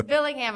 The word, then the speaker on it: Billingham: The first call at the local police station was a report that Mr. Bingham... – Fake name. – I said it wrong. It's Billingham.